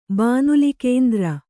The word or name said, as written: ಬಾನುಲಿಕೇಂದ್ರ